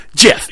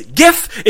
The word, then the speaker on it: GIF: However, I, am here to tell you that he's wrong, and you're wrong if you say GIF. It is GIF. It's spelt with a G, not a J. J-I-F is a peanut butter, pronounced GIF. If you change the J to a G, that is GIF.